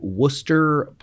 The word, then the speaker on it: Worcester: She went to Worcester Polytechnic Institute in Western Mass. She studied, pronounced that right.